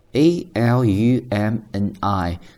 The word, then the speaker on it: alumni: Next one, join your school's alumni association. Most of these associations are much more than social groups. They offer students the chance to find more experienced career mentors in their fields, network with employers, and stay connected to news in their industry. 那第三件事情中呢，几个重点的词。 First one, alumni, a l u m n i，它是一个名词，读作 alumni。